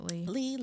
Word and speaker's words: Leigh: And Janet Leigh, I mean, my God, she was a beautiful actress. – I said Leigh. I'm an asshole. Mm. Janet Leigh. – Leigh. – Janet Leigh, it's spelled L-E-I-G-H.